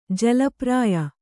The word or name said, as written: ಜಲಪ್ರಾಯ